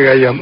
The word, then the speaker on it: Khayyam: Khayyam me enseñó a decir a mí, mi amigo Roy Bartolomeu, que me enseñaba a hablar el persa creyendo que yo aprendía. – ¿Cómo se dice? Entonces yo siempre dije Khayyam. Omar Khayyam.